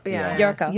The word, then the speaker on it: Yolko: It spells Y-O-L-K-O, but they pronounce it with an R. – Yorko.